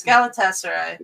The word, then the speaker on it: Galatasaray: It's Galatasaray.